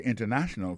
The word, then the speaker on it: international: The international community. Alors accent tomber sous troisième syllabe là.